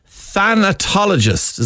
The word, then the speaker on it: thanatologist: Somebody called a thanatologist. Is that correct? – Yes. Very good pronunciation.